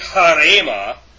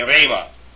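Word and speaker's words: rhema: When used with rho, however, the rough breathing mark is generally not pronounced. So, like if we went back to the word in the line above, instead of saying harema, you just say rema, if that makes sense to you.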